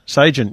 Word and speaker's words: Sagem: Who? Sagem. Yeah, Sagem. Is that how you pronounce that? – Yeah, they're very well-known radio manufacturer.